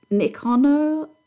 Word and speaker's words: Nikonos: Would you believe a Nikonos? Yeah, is that how you pronounce it? – Yeah.